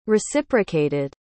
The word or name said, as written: reciprocated